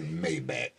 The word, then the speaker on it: Maybach: If he say Maybach one more time, leading these kids down the road to mispronunciation. I lived in Germany. It is not no Maybach, Mr. Ross.